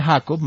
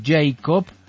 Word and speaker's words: Jacob: Jacob, no se dice Jacob, Mayro, sino Jacob.